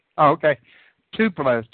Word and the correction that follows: Tulpas: T-U-P-L-A. – Oh, Tulpas. – Oh, I mispronounced it.